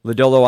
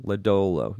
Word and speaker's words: Lodolo: Lodolo obviously has the upside for, am I pronouncing his name? Lodolo. Yeah, Lodolo.